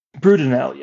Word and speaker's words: Brudenell: Today, we're going to talk about Lord James Brudenell, the Earl of Cardigan. Did I pronounce his last name correctly? – Brudenell, yes.